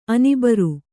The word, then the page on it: ಅನಿಬರ್: anibar; kannada